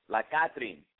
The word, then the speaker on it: La Catrin: La Catrin with a hard C sound.